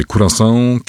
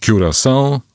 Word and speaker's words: Curaçao: Są bardzo różne wersje. Curaçao, Curaçao albo Curaçao.